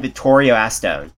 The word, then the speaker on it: Vittorio Astone: Where did you find Vittorio Astone? Am I saying the name right? – That is correct.